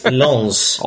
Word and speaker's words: Lens: Yeah, I must say, don't forget the S on the ends. It's Lons.